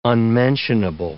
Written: unmentionable

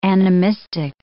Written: animistic

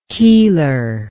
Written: keeler

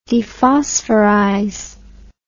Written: dephosphorize

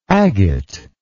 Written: agatoid

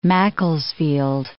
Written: Macclesfield

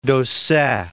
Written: Dausset

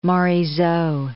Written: Morisot

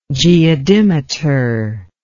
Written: geodimeter

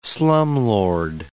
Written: slumlord

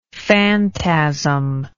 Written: fantasm

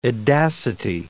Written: edacity

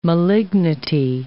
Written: malignity

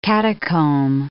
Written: catacomb

